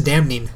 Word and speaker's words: damning: Well, I know, but if you read it, it's a damning. – No, it's just damning, because you know that that N is silent.